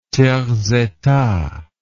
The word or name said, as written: tiers état